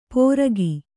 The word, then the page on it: ಪೋರಗಿ: pōragi